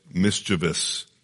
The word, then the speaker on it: mischievous: By the way, how do we say that word? Grievous. Grievous. Likewise, mischievous.